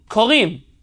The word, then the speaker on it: Korim: So how would you say it in modern Hebrew? – Korin. – Korim. Yeah. Korim is in plural.